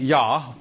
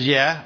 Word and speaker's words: Yah: And it winds up being Yah, pronounced as Yeh or Yah.